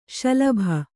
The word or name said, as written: ಶಲಭ